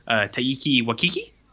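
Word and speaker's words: Taika Waititi: So I watched a product of his home country, and it's 2010's Boy from director Taiki Waititi. And that is the correct pronunciation, as confirmed by a real New Zealander.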